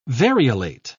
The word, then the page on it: variolate: British English